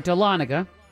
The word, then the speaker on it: Dahlonega: The first 3 satellite mints were in Charlotte, New Orleans, and I'm probably going to say this wrong, but Dahlonega, Georgia. – No, Dahlonega. Yes, you did.